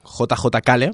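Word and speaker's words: J.J. Cale: ha sonado un Friday de JJ Kale, o JJ Kale, para los que saben pronunciar mejor el inglés,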